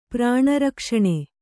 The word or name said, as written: ಪ್ರಾಣರಕ್ಷಣೆ